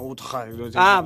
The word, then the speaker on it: Utrecht: No, la pronuncia cambia, no? Utrecht. – Pronuncia olandese.